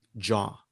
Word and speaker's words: Zhao: So you're thinking Zhao, but the way you pronounce it is Chloe Jaw.